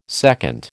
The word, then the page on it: seconde: American English